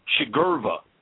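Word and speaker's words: Sugriva: Yes, Hanuman, but Rama was teaching Shagriva, actually, is the pronunciation.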